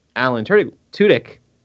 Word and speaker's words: Alan Tudyk: And the fact that Alan Tudyk, You did it.